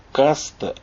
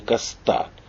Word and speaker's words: Каста: Кстати, из-за корсиканского папы ее фамилия произносится именно как Каста, а не Коста, хотя все у нас произносят именно так – и имя Летиция пишется не по-французски, а более хитро.